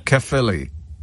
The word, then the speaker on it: Caerphilly: Caerphilly.